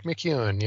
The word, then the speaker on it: MacEwen: Zach MacEwen.